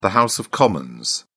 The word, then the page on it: house of commons: British English